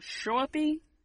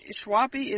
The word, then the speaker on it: Schoeppey: Schwappy, Schwappy. Schwappy is S C H O E P P E Y. Schwappy. Schwappy.